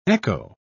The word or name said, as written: echo